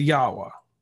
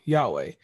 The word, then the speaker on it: Yahweh: Now, your venture, Journey to Yahweh. I pronounced it wrong. – Yahweh.